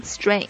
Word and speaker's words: strength: 再就是大家注意一下这个 strength 这个词的发音， strength 里边有一个比较特殊的鼻音，也是很多这个中国同学发不好的地方。